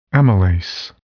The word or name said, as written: amylase